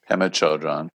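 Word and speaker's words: Pema Chödrön: And you said, another lesson I learned from Buddhism came later as I was listening to a frequent refrain of Pema Chodron. Is that how you say it? – Pema Chodron.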